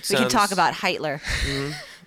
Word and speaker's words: Hitler: I think it's pronounced Hitler. – Hitler, yeah, and he came to power actually in the early 1930s.